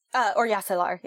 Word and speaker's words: Oryasalar: You know, you don't have an exposition conversation where somebody explains, like, is it pronounced Oryasalar? – Oryasalar, yeah.